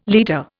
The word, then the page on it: liter: Indian